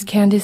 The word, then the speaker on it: Candice: My name is Candice Sandis. It's spelled C-A-N-D-I-C-E. Last name is spelled S-A-N-D-N-E-S-S.